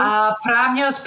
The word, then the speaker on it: Pramneios: Pramnios, Pramnios.